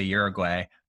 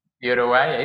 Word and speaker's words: Uruguay: And specifically, I don't say it correctly. I say Uruguay, but you say it better. – Uruguay, it's okay, Uruguay.